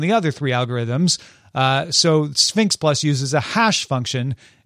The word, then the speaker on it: SPHINCS: And then Sphinx Plus, but it's spelled S-P-H-I-N-C-S Plus,